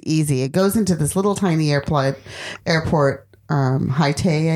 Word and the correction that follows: Hat Yai: I think it's called. – In Haitai. – And no, Hat Yai. – I always say it wrong. That's why I said it like that.